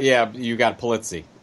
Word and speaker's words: Pulizzi: You got Pulizzi.